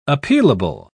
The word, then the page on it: appealable: British English